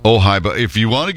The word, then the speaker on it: Ojai: You see, the Wildlife Rehabilitation Center, Ojai Raptor Center, has not collaborated with anybody to make merchandise.